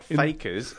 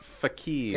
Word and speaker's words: fakir: Well, it's not fakers, it's fakirs. – F-A-K-I-R. – Thank you. – Yes. – Okay. So we are very focused on fakirs.